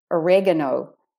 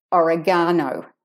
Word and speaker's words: oregano: And the last thing I'm going to mention here is sometimes we stress different syllables in words. For instance, Americans say oregano and we say oregano.